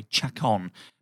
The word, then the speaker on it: Chacon: Chacon. Imagine there's an A where the O is, and we'll be okay.